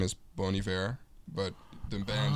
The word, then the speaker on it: Bon Iver: Yeah, it's pronounced Bon Iver.